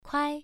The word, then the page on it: kuai: 1